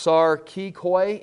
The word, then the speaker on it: sarkikoi: We mentioned, if you look there, the third set of information down there, worldly, on the notes, is the word, it's pronounced sarkikoi in the Greek right there.